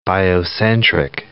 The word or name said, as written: biocentric